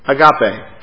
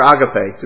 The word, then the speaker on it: agape: The Greek word for love in this scripture is agape, or agape, depending on your pronunciation.